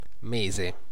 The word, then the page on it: mois: France; [ɛ̃ mwɑ]